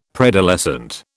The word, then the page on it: preadolescent: British English